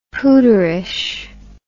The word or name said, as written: pooterish